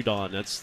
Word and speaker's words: Judon: Yeah, he loves that Judon. It is an emphasis on the second syllable.